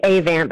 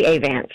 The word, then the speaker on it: Avant: It's actually Avant, but I like how you're making it fancy.